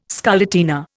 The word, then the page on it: scarlatina: Indian